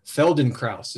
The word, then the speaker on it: Feldenkrais: Feldenkrais.